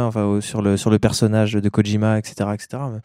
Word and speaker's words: Kojima: Kojima, ouais. – Ouais, tu sais qu'il n'y a pas de D, c'est juste K-O-J-I-M-A. Ouais, c'est comme ça, ouais, écoute, c'est comme ça que ça se prononce, mon cher ami.